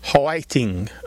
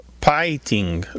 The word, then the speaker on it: hwaiting: It's often spelled H-W-A-I-T-I-N-G or P-A-I-T-I-N-G, and it sounds like three syllables instead of two, so it might be like whiting or pieting, like that. – Yes, and sometimes it sounds like whiting, like with a W almost.